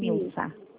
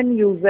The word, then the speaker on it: Nilza: Nilsa? – Mas a pronúncia é Nilza, é com S mesmo, mas é Nilza.